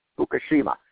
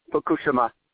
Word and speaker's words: Fukushima: actually the correct pronunciation, not Fukushima, power plant at Daiichi in northern Japan.